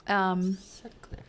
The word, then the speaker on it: Sutcliffe: S-U-T-C-L-I-F-F-E. – Well, I haven't. I kind of got it right. – Sutcliffe. Like, how you think it would sound. Or be spelled. At least for me, anyway. – Sutcliffe.